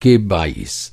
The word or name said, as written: बाइस